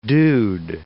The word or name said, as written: dude